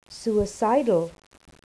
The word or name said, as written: suicidal